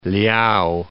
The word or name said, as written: Liao